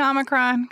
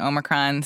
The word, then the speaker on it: Omicron: I've been saying Omicron because that's what the dictionary says is the American pronunciation, but I recognize that a lot of other people are saying Omicron, so I think that this is a safe space for various pronunciations of this variant.